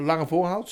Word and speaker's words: Lange Voorhout: Ik heb nu bijvoorbeeld nog vetten lopen op de, zeg ik goed, in Den Haag, de Lange Voorhout, zeg ik het goed.